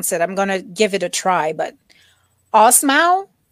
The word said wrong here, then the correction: Osmow: I think. – Oh, what kind of food? – It is, I want to say Greek or Lebanese. I'm not sure. – I'll let you type it, because from what you pronounced I wouldn't even know how to spell it. So. – It's Osmow.